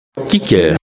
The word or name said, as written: Kicker